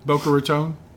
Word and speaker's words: Boca Raton: And is that how you say it there? – What? – Boca Raton? – Boca Raton, yeah.